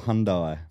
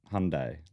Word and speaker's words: Hyundai: Little Hyundai.